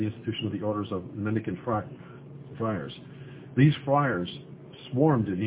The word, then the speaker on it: mendicant: Oh, I think it's a hard K, a hard C sound. – Mendicant, yep. I looked at the legend of the word and I said, yeah, it's mendicant, which it means a beggar.